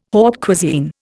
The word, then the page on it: haute cuisine: Indian